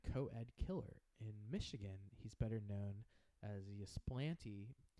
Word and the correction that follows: Ypsilanti: No, how do you pronounce it? Ysplante? – Ypsilanti.